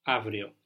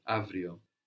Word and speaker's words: αύριο: Αύριο. – Αύριο. This av is spelled AU. We've seen AU can make an af sound, like in αυτό, αυτός, αυτή, or an AV sound, like in αύριο.